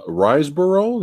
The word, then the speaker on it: Riseborough: Also, we have Andrea Riseborough.